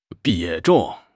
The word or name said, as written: 比重